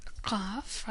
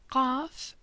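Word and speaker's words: Qaf (ق): Qaf is similar to Q, but pronounced further down in the throat. Qaf, like a little clicking sound almost.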